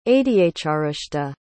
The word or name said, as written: adhrushta